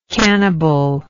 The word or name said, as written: cannibally